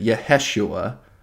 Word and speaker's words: Yeheshua: And the Golden Dawn had their own favored pronunciation of this, and they pronounce it Yeheshua.